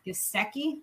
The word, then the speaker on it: Gesicki: Oh, I guess give me Mike Gesicki.